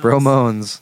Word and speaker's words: Bromones: Bromones. That's spelled like moan. Like, it's Bromones. Yeah.